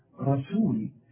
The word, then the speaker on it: رسول: هنا بقى في مشكلة في نطق رسول، ناس كتير قوي تحول لي السين الرؤياء الى صاد، حتى لما يجوا يسبحوا يقول لك سبحان الله سبحان الله، هي سين ولا صاد؟ طب هذه سين رسول، سول،